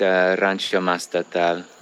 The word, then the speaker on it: Rancho Mastatal: I'm speaking with the founder of Rancho Mastatal. Is there a different pronunciation for that, or is it about right? – Yeah, no, that's correct. Rancho Mastatal.